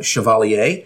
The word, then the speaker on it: Chevalier: Out of 20th Century, we have a film called Chevalier.